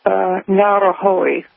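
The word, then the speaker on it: Ngauruhoe: They say that the mountains are called Ruapehu and Tongariro and Ngarahoe. That last one will, you know, get a lot of people, but that's how you say it.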